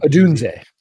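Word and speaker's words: Odunze: Aduzie. Is that how you say it? – Adunze. – Adunze. Adunze.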